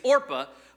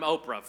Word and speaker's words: Orpah: Fun fact there, Oprah Winfrey is actually, her real name is Orpah, but people mispronounced it so much, she just like said, fine, I'm Oprah from now on.